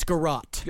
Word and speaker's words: garrote: how is this pronounced, garrote? – It's garrote.